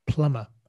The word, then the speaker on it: plumber: Plumber. Again, this time the B is silent, so plumber. – Plumber. – Plumber, but the B is silent in writing, so plumber.